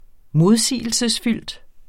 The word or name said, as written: modsigelsesfyldt